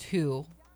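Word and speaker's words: Hu: And H-U, which is pronounced Who, is, well, the English word or the root word for human being.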